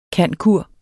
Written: cand.cur.

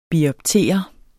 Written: bioptere

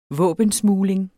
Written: SMUGLER

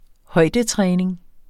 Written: højdetræning

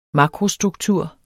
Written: makrostruktur

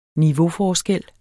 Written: niveauforskel

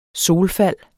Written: solfald

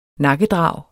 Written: nakkedrag

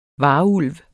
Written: varulv

